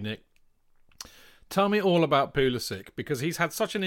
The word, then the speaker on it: Pulisic: And just for all of you American listeners out there who think that Jonathan and I can't say his name, your boy Pulisic.